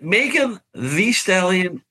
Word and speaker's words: Megan Thee Stallion: Oh, there's an extra E. It's the. – I know it's spelled V, but I feel like I've heard it pronounced the. – That's how Megan goes by.